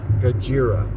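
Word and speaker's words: Gojira (ゴジラ): I know they don't pronounce it Godzilla there. I think Godzilla. – Gojira. – Yeah, or Gojira. – Gojira. – Which translates to Godzilla.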